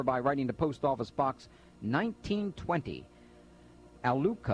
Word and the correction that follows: Alachua: I'll spell that, A-L-A-C-H-U-A, I guess it's Alachua, sorry, thank you, Florida 32615.